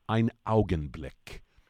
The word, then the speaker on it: Augenblick: The German term, ein Augenblick.